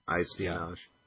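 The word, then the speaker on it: iSpionage: Well, it's like espionage, only with an I. iSpinage.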